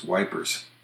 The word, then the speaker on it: Ypres: One of my favorite pronunciations of a foreign country's name popped up during World War I in England, where the town of Ypres, a famous battle was fought, Y-P-R-E-S, was referred to as Wipers.